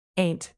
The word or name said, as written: aint